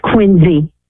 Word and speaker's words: Quincy: Now, I've heard Quincy and I've heard Quincy. What do the locals say? – It's Quincy.